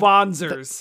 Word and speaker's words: Wanzer: That was so cool when they were like, they're called Vonzers. – The Vonzers, yeah, thank you. I was like, I know they're not, it's not. – It's spelled Wanzer, but it's German, so it's Vonzer.